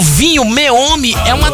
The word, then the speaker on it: Meomi: Então, se você quiser experimentar um vinho gostoso, chama Meome. Meome. M-E-O-M. Peraí, M-E-O-M-I. Chama Meome. Meome. Como se falasse meio homem.